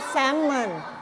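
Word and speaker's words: salmon: แต่ภาษาอังกฤษอ่านว่าแซลมอนแซลมอนไม่ออกเสียง L นะ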